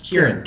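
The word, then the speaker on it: Ciarán: It's Kieran.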